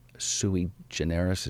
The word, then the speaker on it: sui generis: He's sui generis, is that how you pronounce yeah.